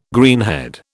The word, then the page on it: greenhead: British English